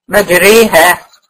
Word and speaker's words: مجريها: مجريها، يعني الالف بتنطق ما بين الالف والياء، دي الالف الممالة بالظبط كده.